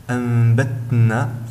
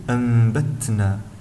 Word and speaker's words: أنبتنا: الملاحظة الأخيرة عن همس حرف التاء في كلمتي أنبتنا، فلا يجب أن ننطقها هكذا أنبتنا، فهنا لا يظهر حرف التاء جليا، يجب أن نظهر حرف التاء بصفته صفة الهمس بخروج شيء يسير من الهواء مع النطق، فنقول أنبتنا.